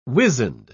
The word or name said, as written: wizened